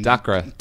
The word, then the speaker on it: Dacre: Dacre.